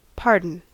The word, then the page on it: pardon: American English